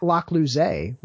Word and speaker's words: Locklouze: But you can find me at Locklouze, which is spelled just like it is in the show notes, and the actual way to pronounce my last name. Most people think it's Lecluse when they see it because I go by Clues, but I only use the middle syllable.